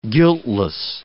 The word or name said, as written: guiltless